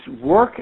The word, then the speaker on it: Rourke: Where can we access the tool, the Work Baby Record? – Sorry, it's Work. It's spelled R-O-U-R-K-E, and many family doctors use this as a tool to monitor development.